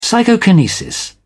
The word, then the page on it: psychokinesis: British English